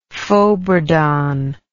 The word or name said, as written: fauxbourdon